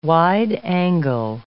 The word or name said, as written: wide-angle